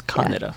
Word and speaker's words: Kaneda: We do our E's a little differently in Kaneda. The King's English, you know, but yeah, it's Kaneda. Yeah. – Kaneda. Kaneda, thank you. I knew I was saying it wrong.